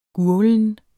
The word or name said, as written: gurglen